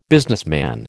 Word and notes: businessman: American English

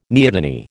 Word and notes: neoteny: American English

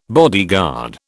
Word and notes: bodyguard: British English

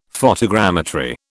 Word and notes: photogrammetry: British English

